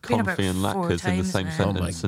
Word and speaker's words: latkes: Bubala's confit potato latkes. Latkes. How would you say that word? – Yeah, latkes. Yeah.